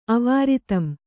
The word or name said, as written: ಅವಿರತಂ